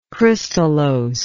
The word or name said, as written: crystallose